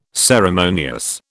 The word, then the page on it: ceremonious: British English